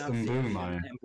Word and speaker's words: Mbeumo: No, the apparently it's Mbeumo. – Mbeumo. – No, because it's not B U E. It's B E U. It's M B E U M O.